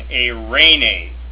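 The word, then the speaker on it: eirene (εἰρήνη): Epsilon, iota, pronounced A, as in eirene, which is the Greek form for peace in the New Testament.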